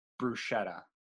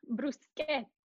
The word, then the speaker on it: bruschetta: So the next one's people say bruschetta. – Oi, bruschetta. – Bruschetta. Bruschetta. I was even guilty for a while of not pronouncing it. It's the sketta. That's like, that's where people get hung up on.